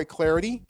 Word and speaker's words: Clardy: You can follow me on Twitter at Troy Clarity. Last name is spelled C-L-A-R-D-Y, at Troy Clarity for all your Twitter following needs.